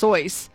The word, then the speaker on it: Seuss: Dr. Seuss, who I understand his name is pronounced Soyce. But Dr. Seuss is how we know him, said his inspiration for the Grinch was himself on a bad day.